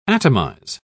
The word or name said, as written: atomize